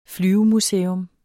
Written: flyvemuseum

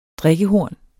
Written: DRIKKEHORN

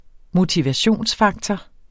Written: motivationsfaktor

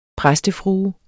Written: præstefrue

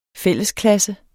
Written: fællesklasse

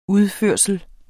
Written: udførsel

